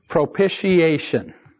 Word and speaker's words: propitiation: Propitiation.